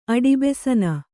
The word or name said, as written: ಅಡಿಬೆಸನ